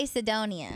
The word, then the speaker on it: Cedonia: C-E-D-O-N-I-A. – C-E-D-O-N-I-A? – Yeah. Is that Sedonia? – I would say Sedonia.